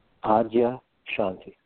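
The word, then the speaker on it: Adyashanti: So you still follow, is it how do you say your teacher's name now? Ozzy Shanti? – Adya Shanti.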